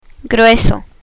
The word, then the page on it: grueso: Spanish